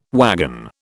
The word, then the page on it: wagon: British English